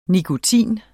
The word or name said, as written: nikotin